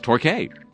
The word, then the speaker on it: Torquay: Last week's story about palm trees in Torbay, England, which I read, included me pronouncing a community spelled T-O-R-Q-U-A-Y as Torquay, but it's actually pronounced Torquay.